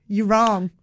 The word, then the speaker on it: Yurong: And we kept getting spat out to this one street and it was called Yurong Street. Look it up. There's a street called Yurong. Yes, I got to look that up because that sounds. – Y-U-R-O-N-G. Yurong.